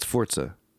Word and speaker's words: Forza: It's not Forza. – I'm not trying to trick you. – It is Forza. – It's Forza. Like pizza.